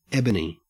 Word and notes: ebony: Australian English

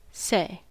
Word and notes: say: American English

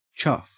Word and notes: chuff: Australian English

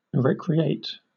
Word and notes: recreate: British English

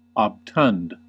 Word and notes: obtund: American English

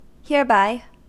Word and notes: hereby: American English

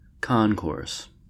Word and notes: concourse: American English

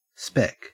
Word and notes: spec: Australian English

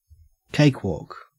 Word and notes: cakewalk: Australian English

